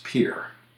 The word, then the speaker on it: Pierre: Now, in terms of pronunciation, a pretty well-known one is the town in South Dakota that's spelled Pierre, but pronounced Pierre.